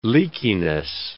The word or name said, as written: leakiness